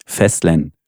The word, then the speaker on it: Fastland: Ja, wie der Däne sagt, ist es wirklich ein dänisches Wort. Es schreibt sich Fastland, wenn man es englisch aussprechen würde. – Ja. – Es ist aber, also das ist so verbrieft und in der Presseinformation so angekommen, das dänische Wort für Festland.